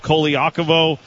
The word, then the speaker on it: Colaiacovo: Is it Carlo Colaiacovo? No, it is not. But that's how we say it, and he's okay with it.